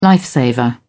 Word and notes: life saver: British English

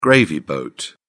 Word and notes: gravy boat: British English

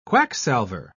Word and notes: quacksalver: British English

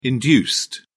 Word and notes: induced: British English

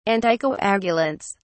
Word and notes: anticoagulants: British English

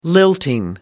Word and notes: lilting: American English

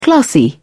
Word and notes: classy: British English

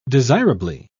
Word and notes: desirably: British English

